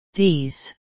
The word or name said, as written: these